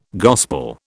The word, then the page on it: gospel: British English